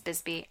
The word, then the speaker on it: Bisbee: Bisbee, Arizona. I hope I'm saying that correctly. – It is. It's Bisbee, and it's haunted.